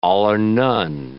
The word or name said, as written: all-or-none